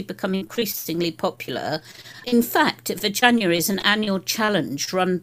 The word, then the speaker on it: Veganuary: Have I pronounced that correctly? – Well, I've called it Veganuary.